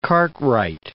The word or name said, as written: Cartwright